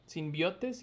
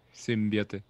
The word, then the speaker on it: simbiote: Simbiotes, simbiontes, no me acuerdo cómo se dice. – Simbiote. – Simbiote.